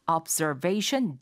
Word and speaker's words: observation: observe를 하는 그런 데크다라고 해서 observation deck라고 말하게 됩니다. – Observation deck. – Observation deck. – Sure. – 이거 옵저베이, 옵저, 앞저 이렇게 말씀하시면 잘 못 알아듣고요. Zer 해서 z 발음이 됩니다.